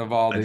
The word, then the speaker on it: Navalny: I Navalny.